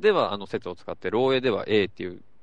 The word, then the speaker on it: 漏洩: 漏洩。 – 漏洩事故とかあるでしょ？ – 漏れるやつね。 – 漏れる、うん。漏洩って、もともと漏洩って読み方じゃなくて、漏雪っていう読み方らしいです。